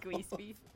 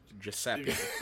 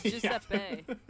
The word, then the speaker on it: Giuseppe: We know it's pronounced Giuseppe. – It's Giuseppe. – Giuseppe. – Because the nickname is Pepe.